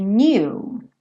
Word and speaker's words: knew: But if you only knew, knew. Então, percebe que esse K é mudo, né? O K do no é mudo, o do passado do no também, new, também vai ser mudo, né?